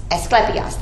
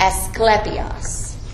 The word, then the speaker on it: Asklepios: His name is Asclepios. I'll practice this at home. Asclepios, that's it. All right, let me spell it. A-S-K-L-E-P-I-O-S. Asclepios. Asclepios.